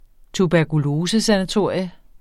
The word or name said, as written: tuberkulosesanatorium